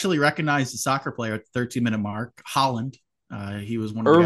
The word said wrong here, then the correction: Haaland: It's pronounced Harland, but that's okay. – Harlan, like Kevin Harlan. – Harland, like Harland. H-A-H-A-R. It's Haaland, but he pronounces it Harland, like it with an R.